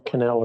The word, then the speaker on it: Cannell: Cannell is spelled C-A-N-N-E-L-L.